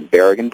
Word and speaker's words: Berrigan: Berrigan brothers, am I saying that correctly? – Yeah.